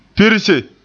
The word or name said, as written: pirse